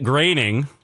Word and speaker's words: Groening: Matt Groening, that's how it's pronounced. I know some people have trouble with that because it's G-R-O-E-N-I-N-G.